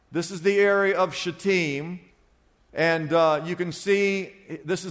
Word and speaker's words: Shittim: I know it looks like something else, but it's pronounced Shittim.